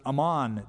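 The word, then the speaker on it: Amman: It's interesting, the same area, Ammon, is called the same thing today, but we pronounce it Ammon, Jordan.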